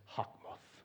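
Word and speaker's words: chokmah (חכמה): In Hebrew, the word is chokmah. If you really want to say it like you're supposed to, it's like in your throat.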